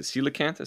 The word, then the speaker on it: coelacanth: And as I pulled this up on Google Images, one of the kind of suggested autofills after, is it coelacanth? Is how it's pronounced? – Yes.